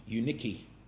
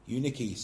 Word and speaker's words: Eunike: that the name Eunice in Greek is actually Eunike. Eunike. So if your name's Eunice, you should be pronouncing it Eunike.